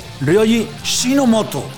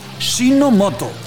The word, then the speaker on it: Ryoji Shinomoto: Voy a intentar lo máximo decirlo como se dice, porque lo he escuchado, he puesto el Google Translator, y es Ryoji Shinomoto. Shinomoto.